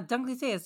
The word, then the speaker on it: Dunkleosteus: Dunkleosteus. – Yeah, Dunkleosteus.